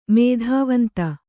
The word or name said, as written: ಮೇಧಾವಂತ